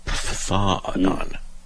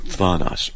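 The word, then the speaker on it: phthonos: It's a PHTH sound. Phthanon. PHTHONON in Greek. Phthanos.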